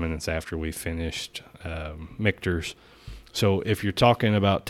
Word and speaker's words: Michter's: Michter's, yep.